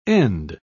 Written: end

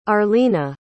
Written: arlina

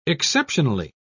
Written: exceptionally

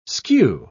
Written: skew

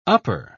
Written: upper

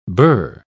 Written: burr

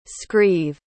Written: scrive

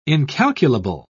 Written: incalculable

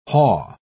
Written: haw

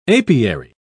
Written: apiary

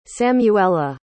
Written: samuela

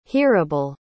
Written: hearable

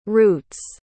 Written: roots

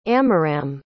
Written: amiram

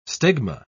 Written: stigma

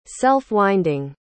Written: self-winding

